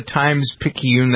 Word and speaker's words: Times-Picayune: Remember the Times-Picayune? That's how they pronounce it. P-I-C-A-Y-U-N-E. They take your word.